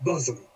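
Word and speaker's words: Bosham: We can see from the Latin tituli up here that the party is riding to a place that's spelled Boshun. But, I have it in good authority, it's actually pronounced bosom.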